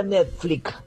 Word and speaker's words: Netflix: Se pronuncia Netflix, porque si decimos Netflix, te sale la S por la X, como que está al final la X.